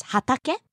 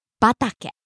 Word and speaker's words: 畑: Hatake or batake means field. When hatake is used by itself, it's pronounced as hatake. But when a word precedes it, hatake becomes batake.